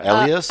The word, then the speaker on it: Elias: I mispronounced Joey Elias's name. – What did you say? – Eli. – Elias? – Elias. – Elias, yeah.